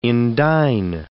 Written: indign

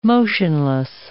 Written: motionless

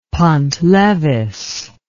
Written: pont levis

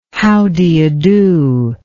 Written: how do you do

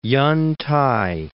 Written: Yen-t'ai

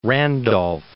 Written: Randolph

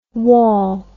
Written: wawl

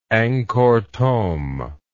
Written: Angkor Thom

